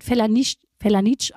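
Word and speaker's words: Felanitx: Ich glaube, das wird Felanitsch ausgesprochen.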